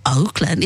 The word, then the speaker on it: Auckland: No, it's not Auckland. It's actually Auckland.